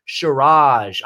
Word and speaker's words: Shiraz: I had to look up how to pronounce that. It's Shiraz. I'm drinking some Shiraz, which is wine made in Australia.